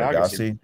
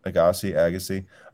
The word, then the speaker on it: Agassi: Pardon me if I say it wrong, but Agassi, I think it is. – Andre Agassi. – Agassi.